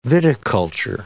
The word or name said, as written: viticulture